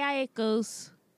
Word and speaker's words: Aiko: Jhené Aiko's, did I say that right? – Yeah, Aiko.